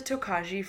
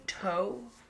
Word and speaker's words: Tokaji: Which I pronounced as Tokaji for literally years because I think it sounds better that way. But see, I was wanting to be like Tokaj. – It's technically Tokai. – Kai. – Yeah. – Yes. – Like with the toe in there. – I was reading about it and I saw it spelt with a K-A-Y.